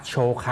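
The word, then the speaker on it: โ ช ไ ค (紹介): ครับคำว่าจิโคนั้นมีความหมายว่าตนเองหรือตัวเองนะครับถัดไปนะครับถ้าดูคันจิก็เป็นตัวที่ 3ตัวที่ 4 นะครับเสียงอ่านนะครับที่เขียนเป็นตัวอักษรโรมันจินะครับเขียนไว้ให้ข้างล่างนะครับคันจิตัวที่ 3ตัวที่ 4นั้นนะครับรวมกันอ่านว่าโชไคครับโชไคครับเสียงโชนั้นออกเสียงยาวนะครับโชไค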